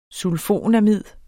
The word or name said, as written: sulfonamid